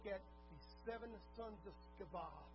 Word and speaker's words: Sceva: How you say his name in Greek. Sceva, Hebrew actually, Sceva. Now, I know you want to say Stephen, but it's not. It's Sceva. You actually pronounce the C there.